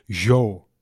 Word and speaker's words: Zhou: Zhou, 2 weken geleden heb ik gevraagd, hoe spreek je nou eigenlijk uit? Ik had toen een interview met hem. Toen zei hij Zhou.